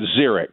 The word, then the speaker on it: Ziriax: And am I saying your name right? Because it's spelled with that extra I. – Yeah, it's Ziriax. That's you nailed it.